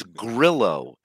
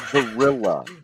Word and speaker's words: Grillo: Steve Gorilla. – Again, this is his only friend that's left from that world, pretty much. And he can't even pronounce his name correctly. It's Grillo, not Gorilla.